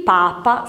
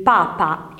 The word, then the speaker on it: Papa: Ok, ci siamo, ricordatevi il papà con l'accento sulla A il Papa scritto maiuscolo senza nessun accento e anche la pronuncia, sentite, il Papa è in Vaticano, il Papà è insieme alla mamma, va bene?